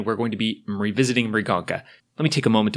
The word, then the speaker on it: Mriganka: A lot of people can't hear the M at the beginning of Mrigonka. Okay, it's spelled like that. M-R-I-G-A-N-K-A. And we are revisiting Mrigonka.